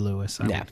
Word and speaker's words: Louis: It is, unless it's pronounced Louis, but yes. – I think it's Ricardo Lewis, yeah. But it is spelled like Louis, like yeah. – L-O-U-I-S. – Correct, which I think as a last name is Lewis.